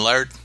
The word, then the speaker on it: Laird: Well worth your while, those original issues of the Teenage Mutant Ninja Turtles by Eastman and Laird. L-A-I-R-D, Laird, I believe is how you pronounce it.